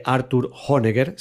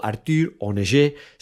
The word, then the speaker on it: Arthur Honegger: Arthur Honegger, si lo pronunciamos a la alemana, o Arthur Honegger, si lo hacemos a la francesa.